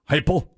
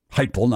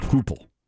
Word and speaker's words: Heupel: The Olympics, UCF with a new coach, Josh Heupel, even though it looks like Hoople. You pronounce that Heupel. – Remember, the hype is real. Heupel. – Yeah. – All righty then. – Heupel, not Hoople.